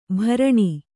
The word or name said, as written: ಭರಣ